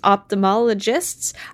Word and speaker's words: ophthalmologist: This is not a replacement for your periodic full eye health exam, but your vision test will be reviewed by licensed ophthalmologists? People who yes. – wear contacts. Tell me how that's pronounced. – It's ophthalmologist. You got it.